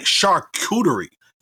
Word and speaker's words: charcuterie: And, and I think I said it right, charcuterie.